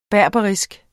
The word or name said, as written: berberisk